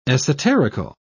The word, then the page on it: esoterical: British English